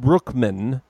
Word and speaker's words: Bruckman: So, spelled Clyde Bruckman, pronounced Clyde Brookman.